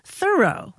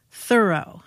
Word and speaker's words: Thoreau: Thoreau, with the accent silent on the first syllable.